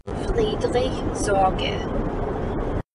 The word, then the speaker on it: Friedrich Sorge: Friedrich Sorge.